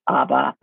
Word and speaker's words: Aba: This town, I'm just going to use the Chinese pronunciation because it's easier. It's Aba, but the Tibetans say Nala or Naba. Depends on the dialogue.